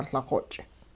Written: Liqoch'

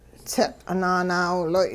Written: tsiq' nanaawiloy'

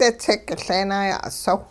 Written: ditsik-Leenaaya'usow

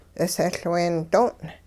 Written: 'isehLween doong'

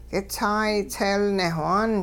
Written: k'itsaay tseelnewhaan

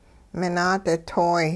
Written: minaaditoy

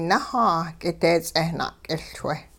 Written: nahaa k'ideets'eh na'k'iLchwe

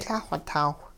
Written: Lah-xw taw

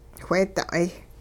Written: wheeda'ay

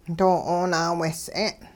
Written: doo-'oonaawehs'e'n

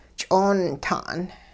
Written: ch'oonta'n

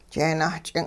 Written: jeenah-ch'ing'

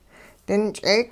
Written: dinch'eek'

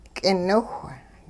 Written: k'iniwh

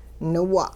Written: niwa'

